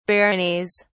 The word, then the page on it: baronies: British English